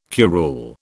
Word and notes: curule: British English

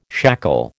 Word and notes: shackle: American English

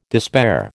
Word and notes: despair: American English

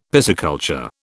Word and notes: pisciculture: British English

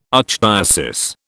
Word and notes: archdiocese: British English

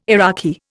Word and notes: Iraqi: Indian

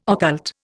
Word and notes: occult: Indian

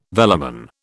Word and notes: velamen: British English

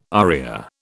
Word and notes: aria: British English

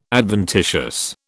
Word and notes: adventitious: British English

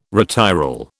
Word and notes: retiral: British English

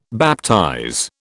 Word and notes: baptize: British English

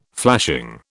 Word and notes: flashing: British English

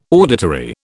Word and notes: auditory: British English

